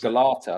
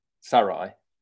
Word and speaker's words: Galatasaray: Um, and I, I, set myself up there for a bit of trivia about Galatasaray, but that's not actually how you say it. Right. It's how is Galata, it? Sarai.